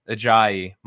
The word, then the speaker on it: Ajayi: Ladies and gentlemen, I want to welcome my very special guest, Michael Ajayi.